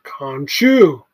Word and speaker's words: Khonshu: Can you actually say it correctly for once? – Khonshu. – All right. Khonshu.